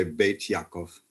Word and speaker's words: Beit Yaakov: It literally means House of Jacob. – Oh, okay. – We would in Sephardic Hebrew say Beit Yaakov.